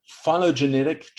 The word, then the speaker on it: phylogenetic: Is it the phylogenetic tree of life? – Oh yeah, it's perfect.